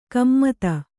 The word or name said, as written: ಗುಮ್ಮತಿ